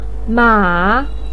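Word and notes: ma3: pinyin; tone 3

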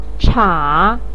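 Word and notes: cha3: tone 3

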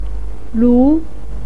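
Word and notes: lu2: pinyin; tone 2

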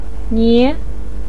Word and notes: nie: 2

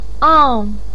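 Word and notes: ang4: tone 4

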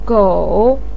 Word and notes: gou3: pinyin

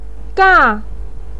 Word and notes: ga: 4

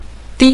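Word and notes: di: tone 5

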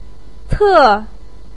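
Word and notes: te: tone 4

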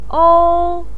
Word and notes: ou: 1

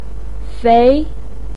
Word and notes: fei2: pinyin

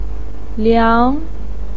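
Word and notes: liang2: pinyin; tone 2

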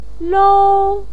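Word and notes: lou: tone 1

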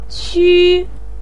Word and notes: qu: 1

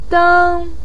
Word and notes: deng1: pinyin

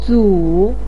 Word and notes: zu: 3

